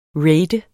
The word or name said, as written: rat